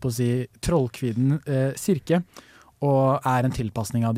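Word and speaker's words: Kirke: Eh, jeg klarer ikke helt å uttale det. – Sirke? – Sirke! Å, oh, det, eh, det, er riktig uttale.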